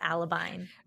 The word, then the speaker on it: Alabine: It's like Alabaster, but Alibine. – It's not a name. – It's not a name. – Okay. What do you admire most about Alibine? – Probably her capacity for hope.